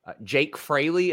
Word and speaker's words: Jake Fraley: We're gonna see Jake Fraley. Is that his name, Jeremy? – Sure. – Is that sure. Let me see. I got his name right.